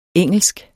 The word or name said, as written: engelsk